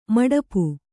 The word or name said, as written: ಮಡಿಪು